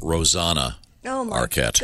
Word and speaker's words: Rosanna Arquette: Oh, and by the way, it is definitely pronounced Rosanna Arquette.